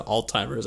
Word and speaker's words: Alzheimer's: I can never say that right. I said Alzheimer's, I think. – Yeah, you did. It's fine.